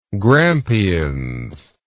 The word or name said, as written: Grampians